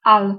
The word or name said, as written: to